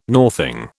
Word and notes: northing: British English

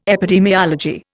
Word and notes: epidemiology: Indian

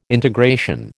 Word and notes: integration: American English

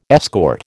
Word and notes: escort: American English

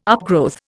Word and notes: upgrowth: Indian

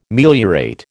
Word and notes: meliorate: American English

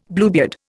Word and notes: Bluebeard: Indian

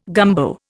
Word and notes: gumbo: Indian

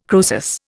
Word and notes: Croesus: Indian

